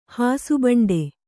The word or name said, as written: ಹಾಸುಬಂಡೆ